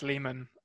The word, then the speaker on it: Liman: Now, the director, Doug Liman, or Lyman, I'm not quite sure how to pronounce it. – I think it's Lehman.